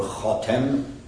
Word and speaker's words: khatem: And here, the word in Arabic language is very tricky, because you could pronounce it khatam, which will become the seal, or khatem, which means the one who puts a finishing end to it.